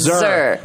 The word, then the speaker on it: xe: It's Zer. – Zer, yeah, mix a lot. – My gender pronoun is XE, but it's pronounced Z.